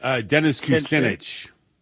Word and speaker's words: Dennis Kucinich: Dennis Kucinich.